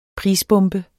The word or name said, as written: prisbombe